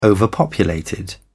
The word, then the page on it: overpopulated: British English